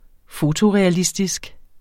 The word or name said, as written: fotorealistisk